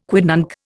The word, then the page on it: quidnunc: Indian